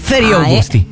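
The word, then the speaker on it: Feriae Augusti: La E e la A insieme si legge E. – Ferie Augusti.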